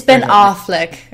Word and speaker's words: Ben Affleck: No, it's Ben Affleck, okay? – Affleck.